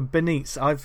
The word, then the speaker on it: Benitez: It's Joe Benitez. I've been saying his name wrong my entire life. I didn't know that.